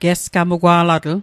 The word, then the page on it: gesgamugwa'latl: Mi'gmaq